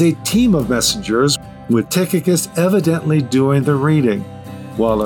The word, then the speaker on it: Tychicus: The performer in this case was more than likely Tychicus, pronounced in Greek, Tychicus, translation, he who is spot on, or just spot on for short.